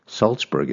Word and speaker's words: Salzburg: Salzburg is my last name. It comes from Austria. There's a city called Salzburg, although it's spelled differently. The first part of my last name has salt in it.